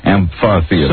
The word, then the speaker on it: amphitheater: No, the encyclopedia, the dictionary, all the pronunciations, including the one Lisa found, all say pha, amphitheater.